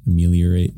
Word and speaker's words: Ameliorate: Track one, Ameliorate. Did I say that right? – Ameliorate, yeah.